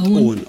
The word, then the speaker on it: Eoin: Also man schreibt ihn Eoin. – Ja, genau. – Also ist bestimmt irisch oder sowas. – Ja, genau. Ich glaube, er wird Owen ausgesprochen.